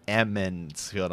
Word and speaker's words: Emond's Field: when I first started the series, I kept calling it Edmonds Field, and the people were like, "It's Emond's Field."